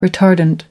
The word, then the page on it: retardant: American English